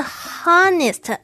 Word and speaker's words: honest: Honest，它的拼法是 H O N E S T。哎，为什么 H 开头的却不是 honest？不是哦，在这里呢，H 是不发音的，所以念 honest，诚实，honest。